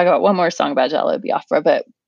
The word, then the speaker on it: Jello Biafra: One is the Game's Jesus Piece, Johnny Cash's The Greatest Cowboy of Them All, and Jello Biafra. Did I say that right? – Yeah. – Okay, Jello Biafra's Jesus Was a Terrorist.